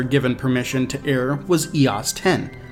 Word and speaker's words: EOS 10: Yeah, we say EOS 10.